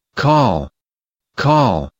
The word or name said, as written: call